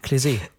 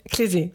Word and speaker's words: Klesi: Jetzt wollte ich dich vorher noch fragen. – Klesi. – Klesi, genau. Ich habe schon wieder vergessen, wie sie ausgesprochen werden.